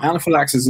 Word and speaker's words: anaphylaxis: I might be pronouncing that wrong. – No, anaphylaxis is correct.